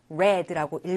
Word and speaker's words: read: 스펠링은 같아요. 하지만 읽을 때 read라고 읽어야 되고 무엇을 읽어주었는지 read의 목적어 그 이야기를 써줘야 돼요.